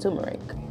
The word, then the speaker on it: turmeric: The next herb is turmeric, as some people pronounce it, to each his own. I believe it's pronounced turmeric.